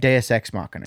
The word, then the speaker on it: Deus ex machina: Deus ex machina.